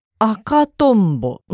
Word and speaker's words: 赤トンボ: 赤トンボ。うん、そうですね。あが低くて、かとが高くて、んぼがまた低いですね。